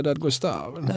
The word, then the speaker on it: Dadgostar: Och sen har jag Alice, säger vi Dadgostar eller Dadgostar tror du? Jag vet inte hur det uttalas. – Ja, alltså, det finns ju en partiledare som heter samma. – Mm. – Och då brukar vi säga Dadgostar. Dadgostar, då säger vi det nu också.